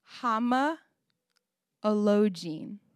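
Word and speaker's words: homologein: and it's hama elogene.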